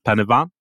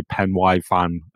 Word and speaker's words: Pen y Fan: So I met up with them and climbed Penavan. I think that I'm saying that right, because I did used to say Pen Y Fan, and I got told off a lot for it.